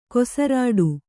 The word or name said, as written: ಕೊಸರಾಡು